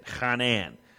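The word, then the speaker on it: chanan (חנן): You got to get something rattling in your throat when you say it. Chanan.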